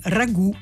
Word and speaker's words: ragù: ma nel frattempo la forma italianizzata, quindi ragù con l'accento sulla U, si è imposta del tutto.